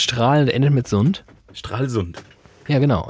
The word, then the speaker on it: Stralsund: Strahlsund. Nein, die Betonung liegt auf Strahl und nicht auf Sund. Strahlsund. Nein, die, die Betonung liegt auf Strahl, nicht auf Sund.